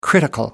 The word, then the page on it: critical: American English